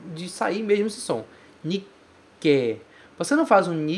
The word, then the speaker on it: Nikkei: Nikkei, veja que existe um tsu pequeno ali. O segundo caractere é um tsu pequeno. Então, você vai pronunciar esse ke com uma pequena pausa antes, certo? Nikkei, Nikkei, Nikkei.